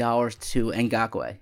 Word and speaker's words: Ngakoue: If on day one of free agency, if they gave $20 million to Ngakoue, am I pronouncing it correctly? – Yeah, I believe so.